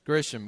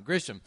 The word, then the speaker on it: Grisham: Or you may like reading Stephen King or Grisham, yeah, I knew it was, I said it wrong. Grisham.